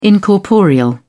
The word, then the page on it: incorporeal: British English